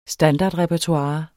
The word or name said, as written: standardrepertoire